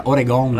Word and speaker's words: Oregon: Oregon si pronuncia alla veneta, Oregon.